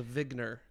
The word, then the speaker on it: Wigner: I pronounce it Wigner crystal, but we're not very uniform about that. Eugene Wigner's been dead for a long time, so he's not around to correct us anymore.